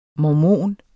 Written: MORMON

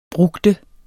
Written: brugde

